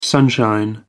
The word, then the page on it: sunshine: American English